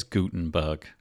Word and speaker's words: Gutenberg: Today, it's the letter G for, and I'm going to try and say it right, Gutenberg. – Yes, I honestly was really reluctant to say it that way. I thought it was Gutenberg, and apparently it's not. Apparently it is Gutenberg, with sort of like a big ooh sound instead of a oh sound.